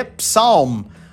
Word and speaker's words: psalm: E para quem gosta de ler a Bíblia, tem o Salmo, que em inglês se escreve P-S-A-L-M e não se lê Psalm. A gente tira o P e vai falar Salmo.